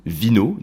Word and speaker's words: vinho: Eh bien au Portugal on dit vino, donc V-I-N-H-O, hein, ça s'écrit, ou euh, vino des Portugal.